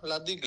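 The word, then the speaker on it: La Digue: Λαντίγκ, έτσι το λένε το νησί, ή σκοτώνω την προφορά? – Όχι, σωστά το λέτε. Λαντίγκ λέγεται.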